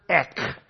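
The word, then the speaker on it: Ek (ἐκ): Feel free to X it out and put in a big magic marker next to it, the Greek word ek. How do you pronounce, how do you spell ek? E-K. Ek.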